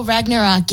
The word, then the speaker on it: Ragnarok: Ragnarok, is that how you say it? – Oh, Ragnarok, yes.